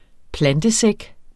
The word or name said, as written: plantesæk